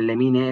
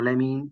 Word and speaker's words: Lemine: Y sabes que Lemine es Lemine y no Lemín porque viene de Cádiz y en Cádiz no se pronuncia así.